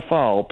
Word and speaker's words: phall: Have you ever had a fall? Phall? – Phall. – Fall. – No. – You say it with an F, but it's spelt with a P.